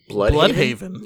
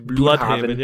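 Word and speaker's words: Blüdhaven: What is it like, Bloodhaven? – Yeah, the city.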